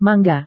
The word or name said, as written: mangga